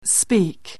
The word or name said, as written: speak